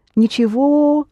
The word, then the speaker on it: ничего: Ничего, the г pronounced the v, and его is pronounced его.